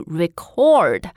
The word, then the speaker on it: record: OK，所以当它是动词的时候呢，它的发音就变成了 to record 啊，record。所以 record 和 record，呃，它发音上面还是有挺大的区别哈。 – Right. It's all about the stress. Are we putting the stress on the first syllable or the second syllable?